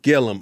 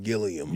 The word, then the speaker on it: Gillum: What's my man's, what's the proper pronunciation of Andrew? – Is it Gillum? – Gillum. Okay, my cousin was saying, I think I was saying Gilliam.